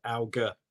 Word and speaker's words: Alger: I think it's French and I think it's Algier, but I'd sound terrible if I said it was that. So I just go with the very London-based Alger hard G.